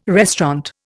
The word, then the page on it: restaurant: Indian